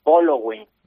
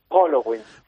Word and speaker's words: Pollo Wing: Yo ya sé cómo se pronuncia. – ¿Tú ya has desvelado el misterio? – Sí, ya he desvelado. Halloween, Polo Wing. – Polo. – Aunque se escriba Pollo Wing, es Polo Wing.